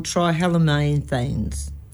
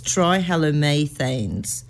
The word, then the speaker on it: trihalomethanes: Well, it's probably because of this thing called trihalomethanes. I said that really bad. – Say it again then. – But I can't say it properly anyway. So it's trihalomethanes.